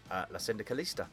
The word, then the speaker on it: La Syndicaliste: La Syndicalista. Did I say it right? – Yes, you did, you jammed it.